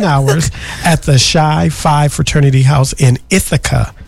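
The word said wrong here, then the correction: Chi: It's pronounced Kai, but go ahead. – Oh, Kai Five? – Yeah, Kai Five.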